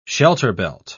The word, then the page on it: shelterbelt: British English